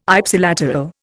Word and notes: Ipsilateral: Indian

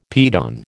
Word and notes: piton: American English

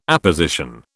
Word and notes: apposition: British English

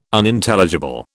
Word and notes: Unintelligible: British English

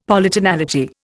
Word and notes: Polytonality: Indian

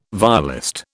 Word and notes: violist: British English

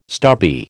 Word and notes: stubby: American English

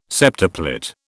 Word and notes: septuplet: British English

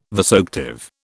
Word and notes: vasoactive: British English

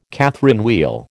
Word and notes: Catherine wheel: American English

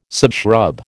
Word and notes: subshrub: American English

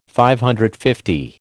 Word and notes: DL: American English

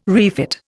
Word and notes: refit: Indian

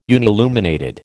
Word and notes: unilluminated: American English

